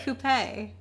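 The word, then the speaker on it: coupé: It's pronounced coupe. – It's got an accent aigu. Why wouldn't it be coupe? – Great.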